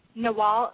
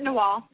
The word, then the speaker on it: Nagual: And that is Nawal, N-A-G-U-A-L. – Oh, Nagual. Okay, okay. – Yeah, cool. They pronounce it Nawal.